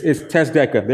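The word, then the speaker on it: tazdeka (צדקה): The second Hebrew word that relates closely to this idea of biblical justice is tazdeka. I'm probably saying that wrong. How do you say that? Tazdeka. It's tazdeka. There you go.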